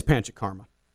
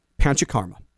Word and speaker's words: Panchakarma: Okay. Panchakarma. Say it with me.